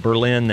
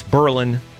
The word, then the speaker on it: Berlin: And if you pronounce it Berlin, then you've never been. You don't know that it's Berlin, not Berlin.